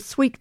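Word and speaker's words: Suite: Louisa Trewatha is an Australian composer, Victorian, and her Sweet Water, which is a play on spelling, which you can't see, but it's S-U-I-T-E, but pronounced, of course, as sweet.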